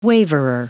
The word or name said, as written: waverer